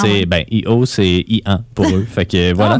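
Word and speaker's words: EO: Ben, ça s'écrit E-O, mais ça se prononce I-1.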